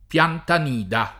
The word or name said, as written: Piantanida